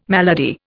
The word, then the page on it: malady: Indian